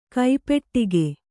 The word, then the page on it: ಕೈಪೆಟ್ಟಿಗೆ: kai peṭṭige